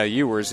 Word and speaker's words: Euers: Yeah, Ewers. His name is spelled E-U-E-R-S, so I'm just trying to get a proper pronunciation on that.